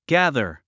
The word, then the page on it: gather: gǽðə r; ギャザァ